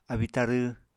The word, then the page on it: abíi-tarw: Apatani